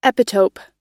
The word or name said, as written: epitope